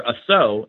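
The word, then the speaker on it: assaut: And the version of Savat that I like the most is called Assault, or Asso is how they pronounce it.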